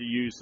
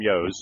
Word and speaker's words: ewe: We like to use the Suffolk and the Dorset. We like to use a Dorset ewe, as we call yos, but properly spelled and pronounced U, E-W-E, but I'll call them yos.